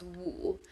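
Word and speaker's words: woo (五): how Chinese people use the number five for crying because in Chinese, five is pronounced woo.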